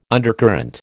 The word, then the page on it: undercurrent: American English